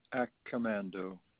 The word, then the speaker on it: Accamando: Anthony Accomando. – Can you spell that for me if you don't mind? – Surely. A-C-C-A-M-A-N-D-O.